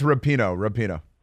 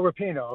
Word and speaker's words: Rapinoe: With Megan Rapinoe, is that how you say it? Rapinoe. – Yeah, just, oh no, it's Rapinoe. – Oh, Rapinoe, okay, yeah. That's what it is.